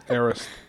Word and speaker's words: Arist: I'm not saying it right, am I? Is that how you say it? – Arist. – How have I been saying it? – You're trying to say Aerith, but, like, with an S after. You're trying to say Aeriths.